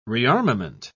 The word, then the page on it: rearmament: British English